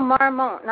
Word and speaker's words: Marmont: No, Marmont, not Montmartre. Opposite. If you take the syllables and switch them, you get Marmont, like Chateau Marmont in L.A.